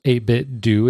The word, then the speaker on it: 8BitDo: I believe it's pronounced 8-Bit Do. It's the number 8, BITDO. I've heard some people pronounce it 8-Bit Do, like sort of like Nintendo, but I'm pretty sure it's 8-Bit Do.